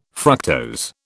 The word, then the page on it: fructose: British English